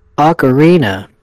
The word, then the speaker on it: Ocarina: Ocarina.